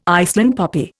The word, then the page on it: Iceland poppy: Indian